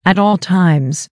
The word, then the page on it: at all times: American English